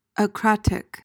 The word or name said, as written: akratic